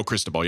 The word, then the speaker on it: Cristobal: All right, so now Cristobal, that's how you say his name, right? – Mario Cristobal, yep.